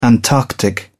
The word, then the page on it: antarctic: British English